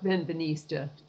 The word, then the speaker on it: Benveniste: I wanted to give you a chance to go into some detail about homeopathy and about the work of, is it pronounced Benveniste? – Yeah, Jacques Benveniste.